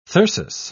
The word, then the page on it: thyrsus: British English